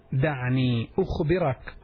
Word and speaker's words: دعني أخبرك: Try to pronounce it as I'm saying it. دعني دال عين نون أنياء. دعني أخبرك. أخبرك.